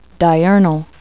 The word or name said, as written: diurnal